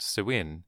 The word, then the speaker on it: Samhain: Their holiday spelled Samhain, which is a Gaelic word pronounced Samhain, was very similar to the present-day Mexican holiday named the Day of the Dead.